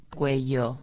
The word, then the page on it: cuello: espanhol